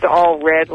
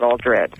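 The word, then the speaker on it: Allred: Well, by the way, just a little, my name is All Red, like all blue. that's the correct pronunciation. It's not Aldred.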